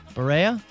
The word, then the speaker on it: Berea: How would you pronounce this? B-E-R-E-A? Berea? – Berea? – Berea? – Yep.